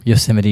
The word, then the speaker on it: Yosemite: Is that how you say it? – What? – Yosemite. – No, Yosemite, you got it.